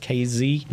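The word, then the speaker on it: Kazee: DeMonte KZ. Is that how you say it? KZ? – Yeah.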